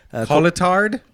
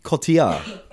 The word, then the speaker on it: Cotillard: Coletard? – Cotillard. – All right. – You said it right.